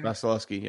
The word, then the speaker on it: Vasilevskiy: side, and then you have Igor versus Vasilevskiy, right? Is that how you pronounce his name? – Vasilevskiy, yeah. – Yeah, I got it right? – Sort of. Somewhat.